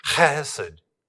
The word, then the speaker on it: chesed: It's pronounced chesed. Chesed. Kind of like you have a fur ball in your throat and you're trying to speak.